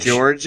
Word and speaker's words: Ligeti: I think she's echoing George Ligeti, you know, the 2001 music, Atmospheres. Ligeti? No, it's George. – George Ligeti? – It's George. It's George Ligeti, yes. – Giacchino. – J-E-R-D-G-E is the pronunciation.